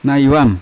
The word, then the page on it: naivam: Yawa